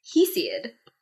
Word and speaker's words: Hesiod: Hesiod.